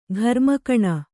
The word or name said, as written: ಘರ್ಮಕಣ